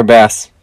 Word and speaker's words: Bass: My kicker, Tyler Bass. I bet he got made fun of for his last name because it rhymes with grass.